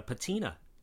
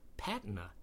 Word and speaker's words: patina: Most people call it a patina, to rhyme with other words that end in ina, like cantina and marina, and with names like Christina and Katrina. But the older, and some would say proper pronunciation, is patina.